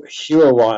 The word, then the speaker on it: Huawei: Well, Huawei, I think it's called.